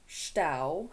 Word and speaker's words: Stau: The German name is Stau, like rhymes with cow. – Stau.